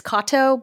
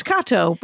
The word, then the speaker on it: Katow: Kato. It's spelled like Katao, so it's right. The temptation is there, but it's Kato, but the W was added in some sort of move into America situation maybe a century ago. – The bastards. So, you should. The love the it. Way they massacred my grandfather's name. Holy smokes. So it's Kato, but it's spelled K-A-T-O-W.